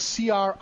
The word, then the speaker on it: Criollo: And that's Criollo. Double L in Spanish is pronounced like a Y.